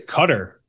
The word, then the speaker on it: Qatar: They say Qatar. I heard that's the actual pronunciation.